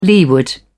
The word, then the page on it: leeward: British English